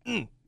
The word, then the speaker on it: Trenton: There is a small town up by where my wife and I are from called, it's spelled T-R-E-N-T-O-N. – Trenton. – Trenton.